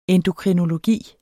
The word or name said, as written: endokrinologi